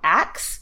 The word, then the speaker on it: Aix: So, before they moved to Paris, they took a pit stop in a city that I believe is called Aix. It's spelled A-I-X.